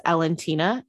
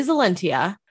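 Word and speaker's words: Elentiya: I originally pronounced Alentia as Alentina in my head. That's not right. – I got Alentia. – Yeah, it is Alentia.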